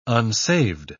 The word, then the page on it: unsaved: British English